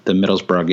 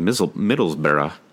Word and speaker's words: Middlesbrough: I mean, Baines actually looked pretty good from an attacking perspective in the Middlesbrough game as well. – Excellent pronunciation of Middlesbrough. By the way. Tried. Yeah, Middlesbrough. I think, right? – Good.